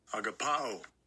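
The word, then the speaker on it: agapaō: Strong's G25 Agapao. Agapao. Agapao. Agapao. The Greek word for love here is agapao. Now, agapao is used a lot in the New Testament, just so that you know, agapao, the Strong's definition of agapao is uh, to love in a social or moral sense, to be loved.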